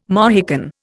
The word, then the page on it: Mohican: Indian